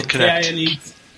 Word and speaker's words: Kinect: No, Kinect. It's spelled like it should be Kinetic, but it's pronounced Kinect.